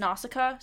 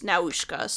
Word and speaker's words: Nausicaa: In the English version, it's pronounced Nausicaa, so... – Okay. – Just so you're aware, yes. – Because I watched the Japanese version, and it was Naushka, so that's where the variation yes.